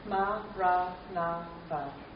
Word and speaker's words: Maranatha: If this is the word you choose, then we say it as four syllables of equal length, Maranatha.